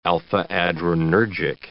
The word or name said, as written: alpha-adrenergic